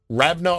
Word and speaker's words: Ravenna: Is that how you would pronounce that? R-A-V-E-N-N-A. Ravenna, Ohio. Never heard of it before. – Close enough.